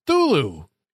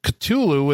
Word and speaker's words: Cthulhu: But she also says Thulu is one of the two acceptable pronunciations of the word that I'm going to say is Cthulhu in my household, the other being the relatively recent Cthulhu with a barely pronounced K and a slightly drawled U.